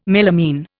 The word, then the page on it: melamine: Indian